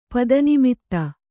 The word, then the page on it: ಪದನಿಮಿತ್ತ: pada nimitta